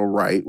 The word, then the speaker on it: Wright: So, you know, you can literally find me on Instagram with my name, literally, which is Ranauldo Wright, which is spelled R-A-N-A-U-L-D-O. And my last name is Wright, W-R-I-G-H-T.